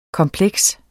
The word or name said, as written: kompleks